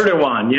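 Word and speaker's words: Erdoğan: I wanted to, the president of Turkey is Erdogan is how it's pronounced. – Erdogan, yeah. – The G is like a W.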